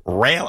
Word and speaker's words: Roehl: R-O-E-H-L. – Yes. – Jeff Rail, I guess it's pronounced.